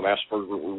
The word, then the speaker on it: Asperger: So, then I took 4 years of German, so Asperger would be pretty close to the German pronunciation as well.